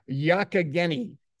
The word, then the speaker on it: Yogyakarta: Was it Yogyakarta? Yogyakarta. Did I get that right? – No. Yogyakarta.